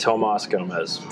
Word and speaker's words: Tomas Gomez: It's Tomas Gomez.